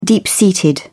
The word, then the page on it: deep-seated: British English